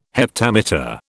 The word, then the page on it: heptameter: British English